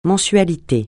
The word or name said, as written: mensualité